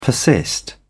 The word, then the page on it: persist: British English